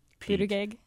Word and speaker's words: Buttigieg: And I'm going to defer on how to pronounce this fellow's name, but Buttigieg.